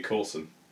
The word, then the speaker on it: Corswain: If it's like Bosun. So Bosun is Boatswain. – Yeah. – So on that basis, it'd be Corson.